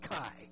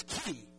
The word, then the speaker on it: chi: And by the way, it is pronounced chi, okay? The Greek fraternities and sororities have corrupted it by calling it chi, okay? But it's chi.